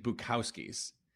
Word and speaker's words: Bukowski's: Okay, so there's a bar in Boston named Bukowski's. And so people know how to pronounce the name. On the menu is a ghost, like Boo, a picture of a cow, and a skier. So Boo, cow, ski.